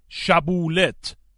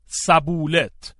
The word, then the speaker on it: شبولت: اگر می‌گفت نه، آن وقت از او می‌خواستند بگوید شبولت، ولی او می‌گفت سبولت و از این راه می‌فهمیدند که او افرایمی است، زیرا افرایمی ها شین را سین تلفظ می کردند.